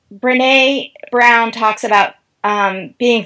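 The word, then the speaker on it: Brené: It's Brene. Brene Brown.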